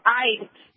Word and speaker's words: Eid: But I'm asking my question really is about this holiday, Eid, that is following this EID. Is that how it's pronounced? – Sure.